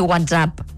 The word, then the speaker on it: WhatsApp: I WhatsApp, pronúncia aguda també, com a servei de missatgeria. – Aguda vol dir WhatsApp, eh? – WhatsApp. – Com si fos una paraula francesa.